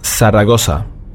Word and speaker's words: Zaragoza: Das Z, Sie haben mit Sicherheit auf dem Festland schon mal gehört, Zaragoza, das wird ausgesprochen wie ein TH auf dem spanischen Festland. Hier allerdings haben wir nur ein scharfes S, Zaragoza.